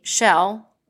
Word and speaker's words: Chelle: The first one was that we have the same first name, Michelle, and my family calls me Shell, with C-H-E-L-L-E, and she goes by Shelley, also spelled with the same C.